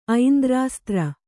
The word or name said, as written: ಐಂದ್ರಾಸ್ತ್ರ